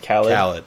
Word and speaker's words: Khalid: Today we're talking about Khalid, this handsome fella right here. – And who yes, we are 99% positive it's pronounced Khalid, not Khalid, yeah.